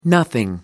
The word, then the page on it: nothing: American English